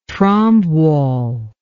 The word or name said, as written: trombe wall